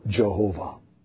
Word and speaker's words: Jehovah: And when you bring that in with the German pronunciation, which pronounces the W as a V, you come with the word Jehovah.